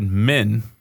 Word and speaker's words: Min: And it was to the god Min. Notice I didn't say it questiony, so everyone believes me. Yep. That's how it's pronounced.